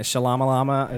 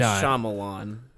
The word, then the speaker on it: Shyamalan: And I think the proper way is Shyamalan.